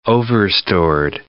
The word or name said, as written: overstored